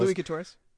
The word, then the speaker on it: Louis Quatorze: But just like, you know, our second episode two, Louis Quattro or whatever Quattro is? – Louis Quatorze. – Quatorze? Quatorze? Mm-hmm. Well, that's how you say it, Quatorze?